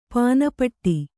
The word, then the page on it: ಪಾನ್‍ಪಟ್ಟಿ: pān paṭṭi